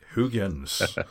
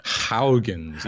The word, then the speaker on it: Huygens: Huygens. – Huygens. Even Huygens. I can't pronounce it. It's a Dutch name for the scientist Christian Huygens, who discovered Titan in orbit around Saturn, in fact.